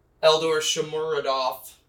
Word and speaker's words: Eldor Shomuradov: But then in the 86th minute, Eldor Shomuradov. I almost butchered that one. – No, you got that.